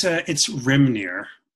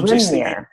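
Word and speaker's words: Hrímnir: It's Rimnir. – So Rimnir. – Yeah, the H is silent.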